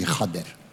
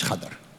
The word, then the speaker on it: Khader: We call him Khader, but it's really Khader, as it's pronounced in the local language, Khader.